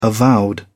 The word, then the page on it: avowed: British English